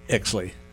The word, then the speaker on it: Oechsli: Exley. – Okay. It's spelled O-E-C-H-S-L-I, so I didn't want to take a chance on butchering it, but tell us about Teresa.